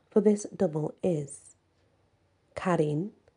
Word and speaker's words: Qarin: Q-A-R-I-N, pronounced Karin.